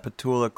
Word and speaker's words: Petula: Petulia? Petula? – Petula Clark.